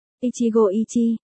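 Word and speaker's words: Ichigo Ichi (一期一会): Nhất kỳ nhất hội Ichi Ihui, tiếng Nhật đọc là Ichigo Ichi, là một quan niệm được phát triển từ trà đạo của Nhật Bản.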